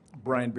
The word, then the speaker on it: Bryan: Brian Baraniski, B-R-Y-A-N, Baraniski, B-A-R-A-N-I-S-K-I.